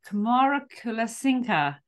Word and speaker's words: Kumarakulasinghe: I am delighted to be joined by Sonali Kumarakulasinghe.